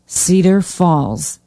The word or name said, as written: cedar falls